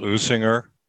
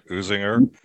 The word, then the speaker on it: Usinger: Add a little Usinger. That's the right way to say it. Usinger.